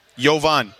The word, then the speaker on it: Jovan Buha: B-U-H-A, Buha. – Johan Buha. – Jovan. – Jovan. – Yeah. – He's never going to get this. – My man. – Yeah. J-O-V-A-N.